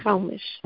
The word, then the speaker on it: chalmish: But when it's the hardness of, then we pronounce it chalmish.